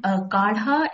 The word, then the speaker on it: kadha: There's something called kadha in the Indian Ayurvedic, you know, diet. – How do you spell that? Actually, it's called K-A-D-H-A. It's called kadha. – K-A-D-H-A.